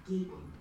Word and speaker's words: Gaten: It's that creep, Gaten, rhymes with Satan.